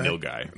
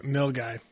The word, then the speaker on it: Nilgai: I'm going to butcher the name. Nagal or not Najal or whatever the Nilgai. – Nilgai. – That'd be badass too. – I was close. – You're pretty close.